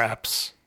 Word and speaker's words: Epps: I also don't know how to pronounce his last name, but Omar Epps? – Yes. Omar Epps.